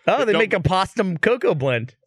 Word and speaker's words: Postum: And you pronounced it wrong, and well, now we all think it's called Potsum.